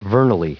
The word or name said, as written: vernally